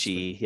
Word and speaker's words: Ishii: I cannot pronounce Ishii, yep.